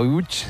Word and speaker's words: Łódź: Aunque se pronuncia Łódź, se escribe Lodz, por si alguien lo busca. – Lodz, sí, Google, sí. No, casi no tiene nada que ver la pronunciación.